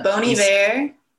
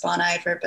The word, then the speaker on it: Bon Iver: Bon Iver. Yeah. It's an amazing skill. Bon Iver, which apparently is really pronounced Bon Iver, but I'm going to stick with Bon Iver because it sounds cool.